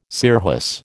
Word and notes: scirrhus: American English

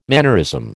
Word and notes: mannerism: American English

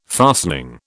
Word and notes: fastening: British English

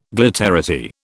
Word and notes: glitterati: British English

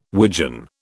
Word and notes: wigeon: British English

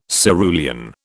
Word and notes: cerulean: British English

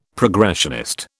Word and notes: progressionist: British English

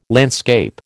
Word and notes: landscape: American English

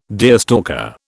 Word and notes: deerstalker: British English

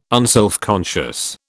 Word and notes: unselfconscious: British English

